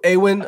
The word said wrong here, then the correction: Arwen: But Arwen. It's Arwen. Fine.